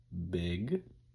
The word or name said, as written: big